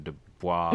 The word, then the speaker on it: Du Bois: You know, um, there there's uh, it's W E B Du Bois, so Dubois. – Mm-hmm. Yes. say it correctly.